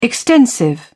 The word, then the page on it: extensive: British English